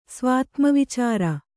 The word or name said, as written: ಸ್ವಾತ್ಮವಿಚಾರ